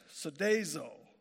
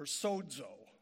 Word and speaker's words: sozo: And the word is sozo or sozo. And it's spelled S-O-Z-O.